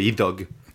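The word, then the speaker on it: Iddawg: Idog, Idog, probablement. C'est euh, le, la digraphie DD en, en, gallois, ça, généralement the, euh, comme un the dans than par exemple. Donc ce serait ouais, Idog, probablement.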